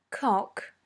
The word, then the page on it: cock: British English